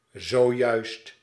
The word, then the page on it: gerade: standard; /ɡəˈʁaːdə/; more commonly; /ˈɡʁaːdə/; /gə.ˈʀaː.də/